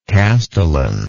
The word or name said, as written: castellanship